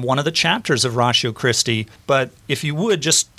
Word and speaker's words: Ratio Christi: They see the word ratio, R-A-T-I-O, and pronounce it ratio like... we would in mathematics, I guess, and then we're saying it ratio, and those who know Latin really well sometimes say ratio, but ratio Christi, as we pronounce it,